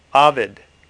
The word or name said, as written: Ovid